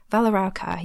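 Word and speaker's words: Valaraukar: The ones that are mentioned here are the Valarauca. I hope I'm saying that correctly. Valarauca, yes, that's right.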